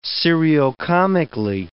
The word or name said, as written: seriocomically